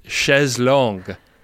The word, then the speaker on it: chaise longue: Yeah, it's a French term, and it's pronounced chaise longue.